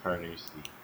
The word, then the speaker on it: Carnoustie: It's Carnoustie.